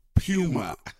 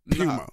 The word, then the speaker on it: Puma: they say Puma. That is the proper pronunciation. Puma.